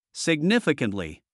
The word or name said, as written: significantly